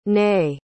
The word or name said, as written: Ney